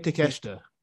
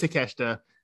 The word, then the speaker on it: Takeshita: Konosuke Takeshita. – Takeshita. – In my mind, I want to say Takeshita every time I see his damn name. I know it's Takeshita.